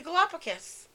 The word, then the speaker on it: Galapagos: The Galapagos.